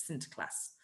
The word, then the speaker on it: Sinterklaas: And the word Santa Claus comes from the Dutch pronunciation of St. Nicholas, which is Sinterklaas.